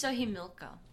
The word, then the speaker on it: Xochimilco: Xochimilco. – What? – Isn't that how you say that? – Uh-huh. It's Xoximilco. That's Chochi.